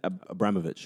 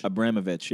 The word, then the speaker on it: Abramovich: Roman Abramovich. – Abramovich, yeah.